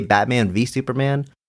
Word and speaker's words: Batman v. Superman: Are you supposed to say Batman v. Superman? Or does the V represent versus? Should we be saying versus? How do you pronounce this? – I think it's Batman v. Superman. I think that's definitely the intended title.